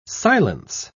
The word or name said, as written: silence